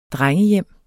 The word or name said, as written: drengehjem